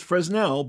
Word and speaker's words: Fresnel: They're very fascinating once you get to study them and understand about Fresnel lenses, and you know, it says Fresnel, but don't pronounce the s.